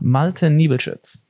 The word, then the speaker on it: Malte Niebelschuetz: Malte Niebelschuetz.